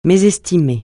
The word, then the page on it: mésestimer: francais